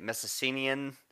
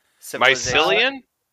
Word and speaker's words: Mycenaean: The agriculture of the how do we say it? Mycenaean Mycelian? – No.